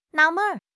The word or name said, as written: 纳闷儿